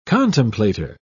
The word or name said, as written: contemplator